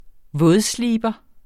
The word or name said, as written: vådsliber